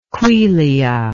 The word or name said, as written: quelea